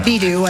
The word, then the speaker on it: Baidu: Is it pronounced Baidu? – Oh, yes. – I would have never... – Baidu, I think, is how they say it.